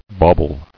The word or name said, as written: Bauble